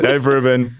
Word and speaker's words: Dave Rubin: His name rhymes with Maeve Boobin. His first name is Dave.